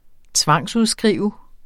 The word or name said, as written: tvangsudskrive